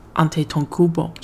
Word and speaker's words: Antetokounmpo: Antetokounmpo，所以后来我才稍稍微微才可以记住他的名，他的姓氏到底要怎么念。